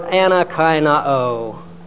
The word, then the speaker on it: anakainao: The verb anakainao. Ana, A-N-A, kainao. K-A-I-N-O, long O.